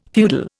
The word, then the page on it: feudal: Indian